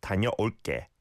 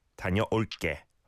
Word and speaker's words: 다녀올게: And the way it's spelt, right? 다녀올게. It's not a 쌍기역 onge, but it is pronounced like a 쌍기역, right? 다녀올게, 다녀올게.